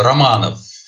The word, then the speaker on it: Romanov: Sim, a pronúncia em russo é Romanov. Nem tudo a gente pronuncia como escreve.